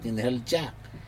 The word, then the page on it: inhelja: Aneityum